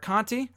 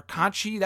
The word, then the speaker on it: Conti: Nikki Cross beating Tainara Conti, or Conchi, that's what Mauro Ranallo was calling her, so maybe you pronounce it Conchi. I thought it was Conti, that's how you would phonetically spell it, or say it, pronounce it, whatever, but apparently it's Conchi.